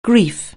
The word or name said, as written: grief